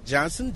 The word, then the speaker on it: Johnson: Charles, C-H-A-R-L-E-S, Johnson, J-O-H-N-S-O-N.